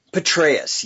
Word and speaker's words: Petraeus: Was it Petraeus? – Petraeus, yes. Rhymes with Petraeus with a B.